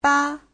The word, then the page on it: 吧: ba5